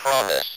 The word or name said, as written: promise